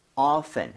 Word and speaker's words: often: often이라고 발음해도 되고요. – 네. – 그리고 often 해서 t를 없애도 상관이 없습니다.